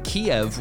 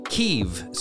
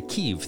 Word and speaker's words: Kyiv: Apparently, I've been saying Kiev, which I have heard all my life, and the Ukrainians pronounce their capital city Kyiv. So moving forward, I too shall say Kyiv.